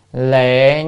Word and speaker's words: lệ: Liệt, hay là chữ này đọc là lệ như sát giới trung thuyết.